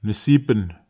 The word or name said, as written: nesipen